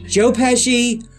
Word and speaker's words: Pesci: Joe Pesci, Ralph Macchio from The Karate Kid, and how do you say that girl? – Marissa Tomei.